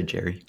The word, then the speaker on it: Gerry: First, I was reading a book called Behind the Superstars, The Business Side of Sports, which is written by, I think it's Jerry. I never know how to pronounce Gerry. – Mm. – But I think I would say Jerry.